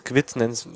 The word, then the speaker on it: GWT: Das ist Google genau, GWT spricht man das aus.